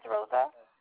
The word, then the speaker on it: Rosa: My name is Rosa. – Can you spell that for me? – R-O-S-A.